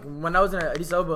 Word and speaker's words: Addis Ababa: Pronounce it properly. Addis Ababa.